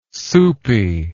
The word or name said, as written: soupy